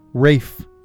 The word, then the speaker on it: Ralph: Rafe is they pronounce his name Rafe, so when you say it, it's what? Rafe Fiennes. Yeah, it's pronounced Rafe. A lot of people don't know that.